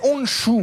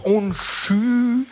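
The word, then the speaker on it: Honshū: C'est Onchou, oui, ou Onchou. Ça dépend des prononciations.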